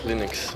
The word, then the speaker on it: Linux: I pronounce Linux. – 142. – As Linux.